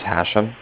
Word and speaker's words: Hashem: My name is Hashem. That's H-A-S-H-E-M. And I'm calling from Cardiff. That's spelled C-A-R-D-I-F-F, like Frank Frank.